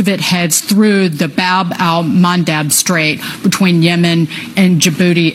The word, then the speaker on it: Bab al-Mandab: Bab al-Mandab Strait. – You did pronounce it right.